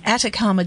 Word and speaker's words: Atacama: Tell me about, and I'm hopeless with pronunciations, the Atacama Desert. – Yeah, no, perfect, spot on.